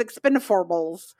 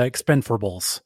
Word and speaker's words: Expend4bles: They have placed the 4 in place of the letter A in the That's word right. So it's expendiforables. – The King's English would pronounce it the expendiforables.